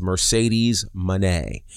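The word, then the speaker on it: Moné: The proper pronunciation is Mercedes Mone, not Mercedes Mone or Mercedes Money.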